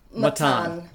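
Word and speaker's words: Mattan: Your novel, The Fortune Men, follows the case of Mahmoud, is it Matin or Matin? How would you say that? – Matan.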